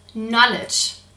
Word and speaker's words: knowledge: Ja, und mit dem Stichwort informiert sein kommen wir zum vierten K der Kinderwunschkontrolle, nämlich dem Wort Knowledge. Knowledge schreibt man mit K am Anfang, das hört man hier nicht.